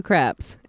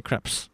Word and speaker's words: crepe: Speaking of beautiful French women, let's talk about aloe crepes. – Who? Aloe crepes. Aloe crepes. – Aloe crepes. – You don't say crepes. It's crepe. – Crepe. – Okay. Yeah, the S is silent, okay? – Okay.